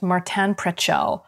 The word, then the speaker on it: Martin Prechtel: But I think one of the things that's been most helpful for me with this is I believe his name is pronounced Martin Prechtel.